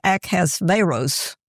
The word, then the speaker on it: Ahasuerus: Now this, I don't know how you pronounce that, King Ahasuerus, the actual pronunciation I listened to, it was Ahasuerus. Yeah, I was surprised too.